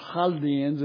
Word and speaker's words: Chaldeans: And you see that in verse 31, Ur of the Chaldeans, or Chaldeans if you want to pronounce it that way.